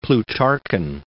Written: Plutarchan

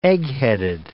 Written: eggheaded

